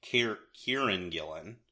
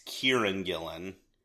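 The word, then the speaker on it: Kieron Gillen: Kieran Gillen. I think I pronounced his name in a way that sounded like Karen in the previous episode of this. It's Kieran Gillen, drawn by Jamie McKelvey, colored by Matthew Wilson, and lettered by Clayton Cowles.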